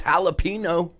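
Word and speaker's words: jalapeño: Which country is it they can't pronounce their J's? Because it would be Yang, Yang, Yang. Anyway, it's Finsters. – I mean, isn't it Mexico, right? Jalapeno is jalapeno.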